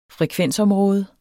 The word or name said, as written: frekvensområde